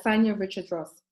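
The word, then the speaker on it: Richards-Ross: Yeah, Sanya Richards Ross.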